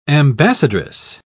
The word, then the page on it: ambassadress: British English